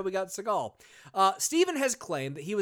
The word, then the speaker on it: Seagal: So first off, his name isn't even pronounced Seagal. It's Seagal. That's how it's pronounced. – As in the bird. – That's basically how it's pronounced. It's the Jewish pronunciation. His father was Jewish.